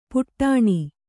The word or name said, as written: ಪುಟಾಣಿ